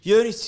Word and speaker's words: Yunis: Hvordan er det man uttaler navnet ditt? Du uttaler det Jørnis. Ikke Jørnis, som nordmenn tror. Men det er Jonis. Jonis. Jonis Yusuf. – Jonis Yusuf Abdullah.